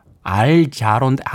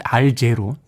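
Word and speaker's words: Al Jarreau: 정진 씨께서 빌리 오션, 캐러비언 퀸, 알자론, 알제로. 네, 뭐, 뭐, 서양 발음이 그렇죠? 알제로. We are in this love together.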